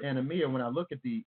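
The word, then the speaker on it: Anomia (ἀνομία): Anomia. – Anomia. Okay. Anomia. That's what it means. Anomia. I mean, that's how you pronounce it.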